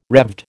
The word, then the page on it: Revd: American English